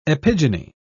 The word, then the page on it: epigyny: British English